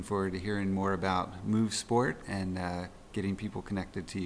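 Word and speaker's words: MUUV Sport: Again, our company is called Move Sport, and that is spelled a little different. So it's M-U-U-V Sport. So again, M-U-U-V Sport.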